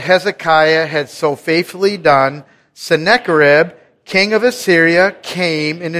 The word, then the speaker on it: Sennacherib: There's one word in there that you might trip up on, but I looked it up and the official Google translations or pronunciation says Sennacherib, not Sennacherib, Sennacherib.